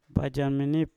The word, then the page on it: bajam minip: mpur